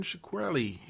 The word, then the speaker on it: Shkreli: Oh, Martin Shkreli. I don't know how to say his name. – Shkreli? Yeah.